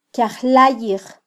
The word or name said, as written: kyajlayij